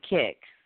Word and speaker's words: Kik: K-I-K, Kick.